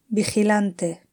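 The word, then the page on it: vigilante: Spanish (Spain)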